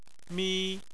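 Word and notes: mi: tone 1

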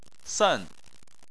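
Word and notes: sen: tone 4

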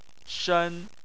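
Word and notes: shen: tone 1

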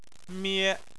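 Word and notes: mie: tone 1

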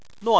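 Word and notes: nuan4: tone 4

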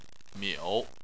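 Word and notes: miu: tone 3

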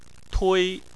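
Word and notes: tui: tone 1

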